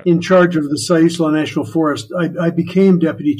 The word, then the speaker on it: Siuslaw: Siuslaw National Forest.